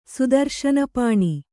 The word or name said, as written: ಸುದರ್ಶನಪಾಣಿ